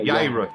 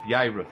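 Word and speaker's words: Jairus: The J in that name is probably pronounced. – It's a Y. Like a Yairus. Yairus, yes. It's probably Yairus, because there is no J in the Hebrew.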